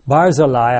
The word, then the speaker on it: Barzillai: Barzillai, I think, is actually the correct pronunciation of that name.